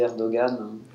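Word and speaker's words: Erdoğan: Mais bon, comme je m'adresse à un public français, je dis Erdogan. – Pour les Français, mais les Turcs disent Erdogan, c'est bien ça.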